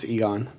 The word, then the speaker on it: Egon: In the movies, it's Egon.